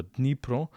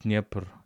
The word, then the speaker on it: Dnipro: Let us remind you that Kherson is located on the river Dnipro, the biggest river in Ukraine, or you can call it Dnieper according to Russian pronunciation, but Dnipro according to Ukrainian pronunciation.